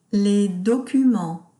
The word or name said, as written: Les documents